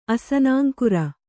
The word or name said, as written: ಅಸನಾಂಕುರ